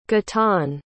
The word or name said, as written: guitain